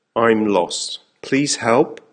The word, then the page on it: I'm lost, please help: American English